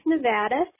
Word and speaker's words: Nevada: Yeah, I just wanted to let you know it's pronounced Nevada.